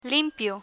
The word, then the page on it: limpio: Spanish